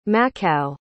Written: machau

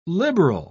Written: liberal

